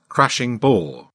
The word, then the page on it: crashing bore: British English